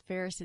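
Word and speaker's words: Pharos: So it's Ferros. It's P-H. – Oh, okay. It's spelled different. – A-R-O-S, yeah.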